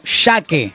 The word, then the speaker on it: MyShake: Pues mira, se escribe M-Y S-H-A-K-E. Ok. Es M-Y S-H-A-K-E, como Shaque. Se escribe Shaque.